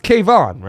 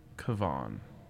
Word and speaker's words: Kevon: First, it's Kayvon, right? With an O. – I think it's Kevon. – Right, but spelled like Kevin, as Austin did, only with an O instead of the I.